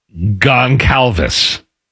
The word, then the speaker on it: Goncalves: The NFL has fined Colts right guard Matt Goncalves.